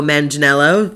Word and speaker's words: Manganiello: Sofia Vergara and Joe Manganiello, that's how you pronounce it, two absolutely stunning people.